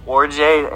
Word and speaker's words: Orji: O-R-J-I. – Please let him have the number 69. – Orje. I'm pretty sure it's pronounced Orje and not Orji.